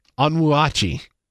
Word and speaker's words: Onwuachi: Onwuachi.